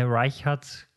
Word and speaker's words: Reichardt: Und deshalb ähm, Kelly Richard, äh, Reichardt, glaube ich, spricht man sie aus.